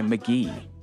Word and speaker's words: MKGEE: And we closed out that last set with "Annabelle" by McGee. McGee spelled M-K.G-E-E. For a little while, I wasn't sure how to pronounce his name, but what really helped was his debut album titled "Pronounced McGee."